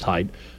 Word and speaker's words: Haidt: Peter Boghossian, James Lindsay, Barry Weiss, names like Glenn Lowry, Stephen Pinker, yada, yada, yada, Jonathan Haidt, it's pronounced Haidt.